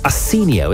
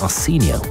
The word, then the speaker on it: Arsenio: So your podcast pick, New Heights by Travis Kelce, featuring Prince William, is Arsenio, isn't it? That's how you say his name, isn't it? Arsenio by Arsenio Hall is your book pick.